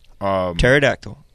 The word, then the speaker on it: pterodactyl: Pterodactyl.